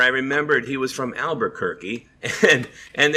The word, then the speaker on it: Albuquerque: And that just stuck in my head as Albuquerque rhymes with turkey.